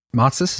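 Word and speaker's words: Matsés: Yeah, I saw a clip of one of them, Matsis, is that how you say it? – Yeah.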